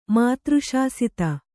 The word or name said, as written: ಮಾತೃಶಾಸಿತ